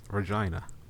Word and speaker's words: Regina: Yes, Regina. That's how you pronounce it, apparently.